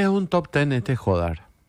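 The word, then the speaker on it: Jódar: Jodar. – Bueno, Jodar dice acá. Jodar es el apellido. – Pero ¿no ves el acento en la O que ponen en tu...? – Ok, no, ahí no tenía.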